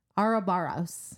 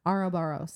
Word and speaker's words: Ouroboros: Keir has in his possession the Arabaros Ouroboros. Is that how they say it in the um, audiobook? – Ouroboros. I say Ouroboros.